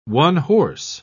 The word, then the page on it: one-horse: British English